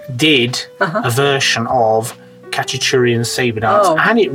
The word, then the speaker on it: Khachaturian: Ca- as Hach- in Khachaturian. – Right. – Khachaturian. I, have heard it pronounced Khachaturian. Yes. Really? Oh, maybe not in. So yeah. Armenian pronunciation would be Aram Khachaturian.